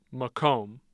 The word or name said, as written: Macomb